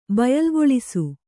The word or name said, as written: ಬಯಲ್ಗೊಳಿಸು